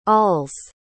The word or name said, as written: all's